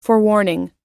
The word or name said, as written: forewarning